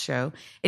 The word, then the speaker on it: Sjo: He's a very successful musician in Europe, S-J-O, but it's pronounced Sho.